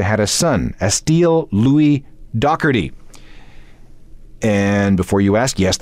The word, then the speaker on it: Doherty: Oh, by the way, uh, Doherty is how you do pronounce Pete Doherty's last name. That's the way the British do it.